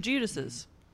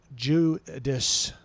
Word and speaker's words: Judas: Judas. – See, why are you overpronouncing the Jew part? – Because he was such a bad part.